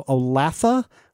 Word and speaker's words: Olathe: The town is pronounced in three syllables, so Olathe rather than Olathe.